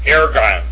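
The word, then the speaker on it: Ergon (ἔργον): Ergon. Epsilon Rho. Gamma. Omicron Nu, with a smooth breathing mark over the epsilon, and an accent on the epsilon. So therefore, it would be pronounced Ergon, Ergon, Ergon.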